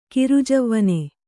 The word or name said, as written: ಕಿರುಜವ್ವನೆ